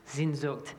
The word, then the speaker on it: Sehnsucht: which is Sehnsucht. Sehnsucht.